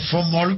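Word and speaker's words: von Mohl: Para von Moll, que yo lo pronuncio en alemán, von.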